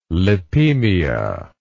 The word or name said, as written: lipemia